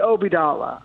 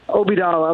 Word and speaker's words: Obeidallah: Have I been mispronouncing your name? – You know, I'm not a stickler for how people pronounce it, because people have spun it so many different ways. From, I say Obidala.